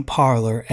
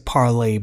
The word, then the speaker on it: Parler: It's an app, M-I-N-D-S. Go follow me. I know I keep saying Parler and they pronounce it Parlay, but I'm going to say Parler anyway. Go follow me on Parler, P-A-R-L-E-R.